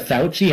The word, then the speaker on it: Fauci: She's not getting the Fauci ouchie.